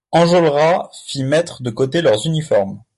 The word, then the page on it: uniformes: French